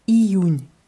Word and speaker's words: Июнь: Auf Russisch wird er mit I vorne geschrieben. I-U-N, Merkgesnack, Iun. Und dieses I muss man auch deutlich aussprechen. Iun.